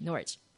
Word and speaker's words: Norwich: Yes, I did have to Wikipedia how to pronounce that correctly. And that's apparently how you do it, Norwich.